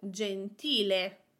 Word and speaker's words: gentile: For example, to say nice, we use the word gentile, gentile. Here we have three syllables, gen-ti-le. I am putting the emphasis on the syllable before the last one, ti. Gentile, gentile.